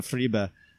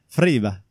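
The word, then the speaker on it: Friebe: I was listening to the cycling podcast, and Daniel Friba, that's how they pronounce it, Friba, recalled an article from several years ago in LA Keep of all these riders with their comments about Terpstra.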